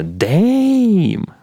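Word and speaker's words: DAMN: Дем я так, типа, безэмоционально говорю, она такая, типа, Дэйм, Да, вот именно так надо произносить эту, эту великую пластинку Кендрика.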